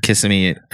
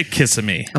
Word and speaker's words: Kissimmee: I've heard it pronounced Kissimmee. – Yeah. We'll say Kissimmee.